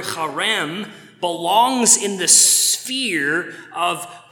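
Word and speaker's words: Charem (חרם): The verb used there in verse 34 is a form of the Hebrew word charem. You know you're saying it right if you spit a little bit. Charem.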